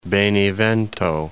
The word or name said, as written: Benevento